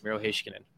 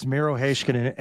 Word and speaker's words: Miro Heiskanen: So yes, that is how it is pronounced, Miro Heiskanen. – Okay, so it's Miro Heiskanen.